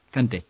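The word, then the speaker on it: Cantet: la classe di Lauren Cantè, non so, se, Lauren Cantè, come si pronuncia? Vabbè, Cantè. – Cercate la classe, Cantè, Cantè, Lauren Cantè, che secondo me è un gran film e...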